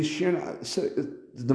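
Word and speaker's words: Cioran: I think it's spelled Ciorn, Sheeran, yeah, Ciorn. Yeah, and I just had a little break right there.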